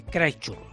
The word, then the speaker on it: Christchurch: Christchurch. ¿Lo he dicho bien? – Lo has hecho cojonudo.